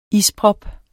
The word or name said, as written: isprop